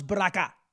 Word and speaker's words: Baraka: How many of you folks in the black community agree with Roz Baraka? Rhymes with caca.